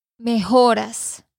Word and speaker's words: mejoras: Mejoras. – Ah, grandes mejores por el año. Mejoras. – Mejoras. – Mejoras. – Ok. – Con una A. Dilo de nuevo. Mejoras.